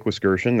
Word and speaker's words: Wiskerchen: So Jake Wiskirchen, that is how the name is pronounced.